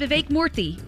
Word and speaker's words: Vivek Murthy: Surgeon Vivek Murthy. Is that how you say it? – So sort close, of. Vivek Murthy. Yes. – That's very close. Murthy, okay.